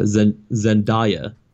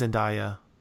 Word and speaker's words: Zendaya: Zendana, I think her name is, if I'm saying it right, even uh, Zendaya. – Yeah, um, her, uh, yeah, I think it's Zendaya.